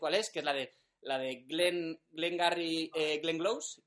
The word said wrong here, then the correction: Glengarry Glen Ross: que es... Eh, eh, ostras, no sé, si lo he dicho bien exactamente la película. Esperad, un segundito. Glenn, Gary, Glenn Garry, Ross, vale.